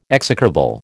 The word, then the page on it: execrable: American English